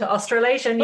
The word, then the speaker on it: Australasian: Okay, so you were invited to be a member of the Royal, is it Australasian? Australasian, mm-hmm. How do you say that? – That's the word, Australasian. You got it.